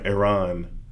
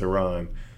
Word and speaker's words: Iran: I understand the fear of nations like North Korea and Iran and Iran. Sorry, I pronounced it wrong. It's Iran. I